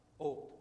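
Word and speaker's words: Ot (אות): And it also spells out this Hebrew word, which is pronounced Ot, or the sign or the seal.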